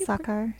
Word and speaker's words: Sachar: Louis Satcher... Sachar, is that how you pronounce it? How it's Sachar.